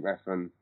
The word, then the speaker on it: Refn: Refn, is it? – Yeah, The Neon Demon.